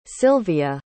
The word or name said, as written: Silvia